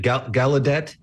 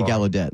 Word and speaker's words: Gaudette: Is it Gaudette? – That's what I've heard pronounced before, and that's what I say. So we'll both say Gaudette.